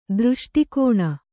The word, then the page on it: ದೃಷ್ಟಿಕೋಣ: Kannada